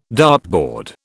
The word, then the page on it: dartboard: British English